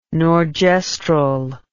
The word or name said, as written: norgestrel